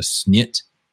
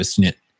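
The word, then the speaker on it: Schnitt: If you're in Germany, you would ask for, I believe it's pronounced a snit, and it's like, it's basically a pour of beer, but it's half foam.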